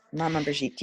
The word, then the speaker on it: Maman Brigitte: Maman Brigitte.